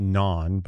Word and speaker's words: Bnonn: And his name is pronounced Non, but it's spelled B-N-O-N-N.